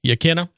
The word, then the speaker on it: Yaquina: Hey, I was reading this morning that they ended up reinstating the tour guides over at, is it Yaquina or Yaquina? I forget how you pronounce that up there. – Yaquina. – Okay.